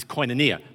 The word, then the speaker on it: koinonia: Actually, the Greek pronunciation is koinonia, but we in English call it koinonia.